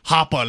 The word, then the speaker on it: Hoppe: Blockchain Bellwether says it's pronounced Hoppe, not Hoppe. So they must be a fan. They must be a fan of... It's like Hoppe, like get to the Hoppe.